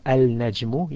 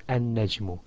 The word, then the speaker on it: النجم: You either pronounce the al completely or you join the lam into the first letter of the word after it, such as instead of al-najmu, you said an-najmu.